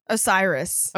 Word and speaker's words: Osiris: Osiris. – Osiris. Thank you. Yeah, just correct all my rude pronunciations.